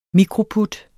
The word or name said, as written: mikroput